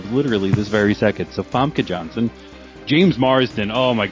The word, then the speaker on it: Famke: Famke Janssen. – Famke. – I believe that's how you say it. – It's so nice. Famke. I like that. F-A-M-K-E.